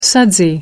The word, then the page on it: sudsy: American English